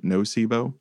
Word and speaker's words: Nocebo: I believe is how you pronounce that, like placebo, but Nocebo.